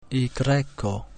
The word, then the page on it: Y: igreko